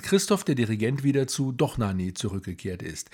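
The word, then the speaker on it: Dohnányi: Der Akzent auf dem A in Donani hat übrigens nichts mit der Betonung zu tun, sondern besagt nur, so ist das im Ungarischen, dass das A offen und lang ausgesprochen wird, also A und nicht O.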